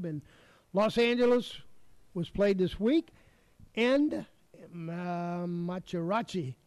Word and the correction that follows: Hideki Matsuyama: Hideki Matsuyama.